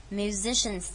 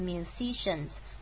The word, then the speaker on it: musicians: OK, every time musicians pick up their instruments, musicians 这个字呢，请注意这个 si 的地方我们念 z 很重要哦，请不要念成 musicians, 那这个声音这个字就会造成大家的困惑了，就是听的人可能就有点困惑你在讲什么，所以一定要强调那个 musicians z 也是 z 的声音。